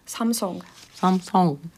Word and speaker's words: Samsung: Samsung. Ar O, jā. – Samsung. – Viņi tā kā... arī visur liek to uburtu, jā. – Bet īstenībā viņi izrunā kā O. Tāds garenāks O.